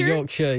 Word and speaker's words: Yorkshire: Like Yorkshire, yeah.